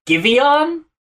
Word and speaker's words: Giveon: I did like Giveon.